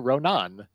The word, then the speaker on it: Ronan: And he said Saoirse Ronan with the emphasis on the last syllable.